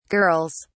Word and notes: girls: British English

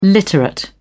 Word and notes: literate: British English

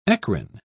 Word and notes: eccrine: British English

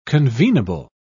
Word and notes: convenable: British English

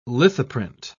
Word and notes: lithoprint: British English

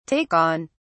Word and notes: take on: British English

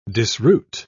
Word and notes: disroot: British English

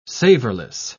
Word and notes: savorless: British English

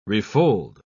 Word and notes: refold: British English

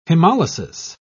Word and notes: hemolysis: British English

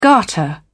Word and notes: garter: British English

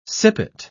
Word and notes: sippet: British English

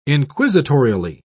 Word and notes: inquisitorially: British English

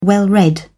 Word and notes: well-read: British English

